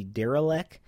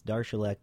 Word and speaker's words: Darilek: And if you look at his name on the program, his name looks like maybe Derelek, but it's actually a Czech name, and it's Darshalek is how you pronounce it.